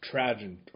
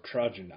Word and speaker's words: Trajan: He was condemned by the Emperor Trajan, or Trajan. I think I'm saying that right. T-R-A-J-A-N. – Yep.